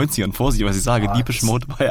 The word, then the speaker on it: Depeche Mode: Guck mal, du sprichst auch richtig aus, ne? Ich schreibe Depeche Mode, Depeche Mode. – Habe ich richtig gesagt, oder? – Du hast richtig gesagt.